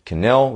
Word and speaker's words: K'nell: I've also got my personal page, Tristan Cannell, which is spelled K'NELL.